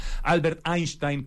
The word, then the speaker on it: Einstein: voy a pronunciar como si fuera mi abuela alemana, Albert Einstein College of Medicine en Nueva York en 2014.